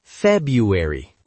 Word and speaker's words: February: February. Muitos falantes nativos dizem February, quase omitindo o primeiro R.